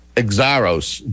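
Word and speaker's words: Xiarhos: We have State Representative Steve Exaros joining us. I hope I said that right. – You nailed it.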